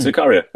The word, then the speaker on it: Sicario: Sicario, that's it. – Am I saying that right? Yeah. Oh, good.